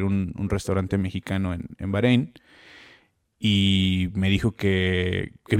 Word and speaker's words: Bahrein: Va, ¿Cómo se pronuncia? – Bahrein. – ¿Bahrein? Bahrein, sí.